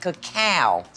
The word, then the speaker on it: cacao: You know, they were talking about that cacao. – Mm-hmm. – Is that how, am I saying it right? – You are, so that refers to, cacao refers to the bean before it's been hulled and fermented.